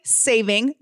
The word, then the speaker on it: saving: Daylight saving, no S, time.